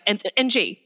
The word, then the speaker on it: Ng: People can't pronounce my last name because it's just two letters and it's two consonants. So it's like NG, right? So it's just ng.